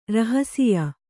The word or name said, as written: ರಹಸಿಯ